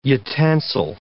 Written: utensil

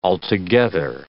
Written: altogether

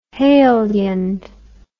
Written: heliand

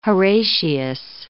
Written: Horatius

